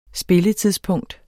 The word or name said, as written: spilletidspunkt